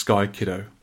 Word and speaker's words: Sky Kid: I think it's just pronounced Sky Kiddo mm. – in Japanese.